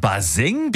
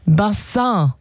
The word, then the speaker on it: Bassin: Ja, es kommt eigentlich aus dem Französischen. Bassin. Und bedeutet Becken oder Wasserbecken.